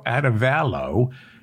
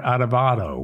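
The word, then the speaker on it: Arévalo: Bernardo Adovalo, again, somebody told me it's pronounced that way. Bernard Adovalo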